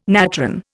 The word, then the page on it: natron: Indian